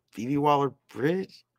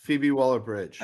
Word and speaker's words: Phoebe Waller-Bridge: Phoebe Waller-Bridge? – Yes, Phoebe Waller-Bridge.